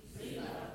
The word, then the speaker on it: Ziba: Ziba. – Z-I-B-A is how we spell it in English. Ziba.